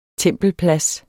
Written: tempelplads

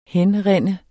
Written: henrinde

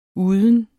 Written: uden

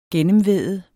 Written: gennemvæde

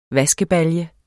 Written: vaskebalje

